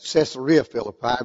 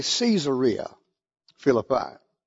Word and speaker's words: Caesarea Philippi: I've always pronounced it Caesarea Philippi, but it's actually Caesarea Philippi.